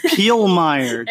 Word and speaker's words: Pielemeier: I can't pronounce your last name. – Pielemeier.